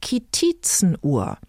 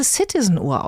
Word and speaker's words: Citizen-Uhr: Genauso wie mit der sogenannten Kitizenuhr, bei der ich erst wirklich Jahre später feststellte, dass es möglicherweise Citizen-Uhr ausgesprochen wird.